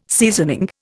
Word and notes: seasoning: Indian

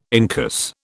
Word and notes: incus: British English

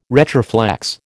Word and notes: retroflex: American English

